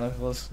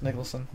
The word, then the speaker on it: Nicholson: I like I just liked him better than Jack Nicholas Nicholson.